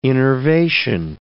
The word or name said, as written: innervation